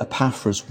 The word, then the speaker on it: Epaphras: E-P-A... – I can't spell it. – E-P-A-P-H-R-A-S.